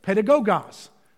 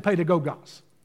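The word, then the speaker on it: pedagogos (παιδαγωγός): In verse number 24 in verse number 25, he uses the term tutor, or in the Greek text, pedagogos. Pedagogos is the term. And uh, I want you to learn to say that. Uh, repeat it after me, syllable by syllable. Pay, Da, go, gos. Well, bless your neighbor by saying pedagogos.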